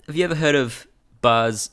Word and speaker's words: Bas: No, it's Baz, but it's pronounced Bas in like Germanic areas.